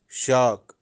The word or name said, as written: shaak